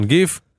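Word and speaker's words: GIF: והנאום שלו הנאום שלו זה פשוט סרטון גיף. It's pronounced ג'יף.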